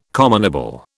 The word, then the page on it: commonable: British English